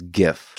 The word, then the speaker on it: GIF: How do you say it? What's the right way to say it? – The correct way is GIF.